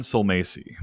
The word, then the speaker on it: Sulmasy: Dan Silmacy. – Silmacy. How would you spell that? – S-U-L-M-A-S-Y.